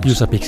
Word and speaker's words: USAPEEC: Yusapik se escribe U-S-A-P-E-E-C.